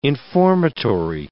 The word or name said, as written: informatory